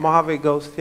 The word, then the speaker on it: Mojave Ghost: Mojave Ghost, yeah.